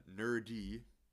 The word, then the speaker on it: Nur-D: It's Nerdy. It's spelled NUR-D.